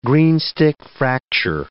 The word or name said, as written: greenstick fracture